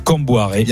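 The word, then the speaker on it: Kombouaré: Camboaré.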